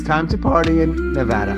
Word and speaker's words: Nevada: I do want to fight you on the pronunciation of Nevada. – Oh, shit. Nevada. – Nevada. – Oh, But there's no option to say it another way? – No, everybody here cringes when we hear Nevada. – Why do so many people say it incorrectly, do you think? – Because nobody corrects them.